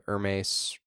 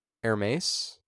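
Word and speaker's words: Hermès: Am I still not pronouncing that correctly? – How'd you say it? – Hermes? – No. – Is that not right? – It's Hermes.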